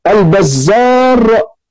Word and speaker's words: البزار: مثلا البزار بالراء المهملة والبزاز بالزاي المعجمة، البزار والبزاز هذه نسبة، هو ما اتفقت صورته في الخط اهو بزار وبزاز الاثنين متفقين في الخط ولكن اختلفت في النطق.